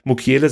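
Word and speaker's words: Mukiele: Also Mukele, so wird er richtig ausgesprochen.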